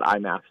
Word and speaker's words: IMMAF: Tell me how to say that properly. – We just call it IMAF, the International Mixed Martial Arts Federation.